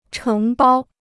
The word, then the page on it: 承包: chéng bāo